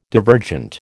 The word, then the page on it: divergent: American English